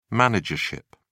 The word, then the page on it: managership: British English